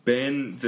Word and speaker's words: been: Okay, so 그거는 벤이고 그 다음에 아 그리고 미국 사람들이 발음을 할 때 그냥 벤이라고 해요. 빈이라고 안 해요. 이거는 벤 the 이름처럼 말해요.